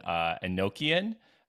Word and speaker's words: Enochian: But just for our listeners, part of the magic system in this novel is like sigil magic, and it's based in Enochian.